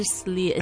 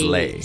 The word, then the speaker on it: sleigh: 对，不是 sleigh, it's sleigh. Santa and his sleigh. – Right, and it rhymes with way.